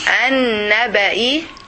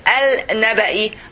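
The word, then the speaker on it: An-Naba'i (النبأ): Now I will ask you, this lamb in the word an-naba, is the has to be clear or not? You pronounce it or not? – No. – Very good. Because it is followed by the letter Hadashad. So you see how I pronounce it? I said An-Naba'i. An-Naba'i. Alif Noon. I didn't say Al-Naba'i. I say An-Naba'i.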